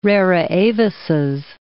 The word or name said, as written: rara avises